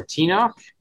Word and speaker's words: Tenoch: But it's Tenoch Huerta, or Tenoch. – Oh, you nailed it. Perfect.